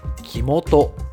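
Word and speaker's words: 生酛: お酒という字の変、鳥年の鳥変に元気の元がついて元と読むそうで、この字が今お話に出てきた酒母を指すそうなんですが、この元の前に生がついて木元と読むそうです。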